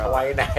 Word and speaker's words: Hawaii: So let's start our Hawaii. W pronounced like a V.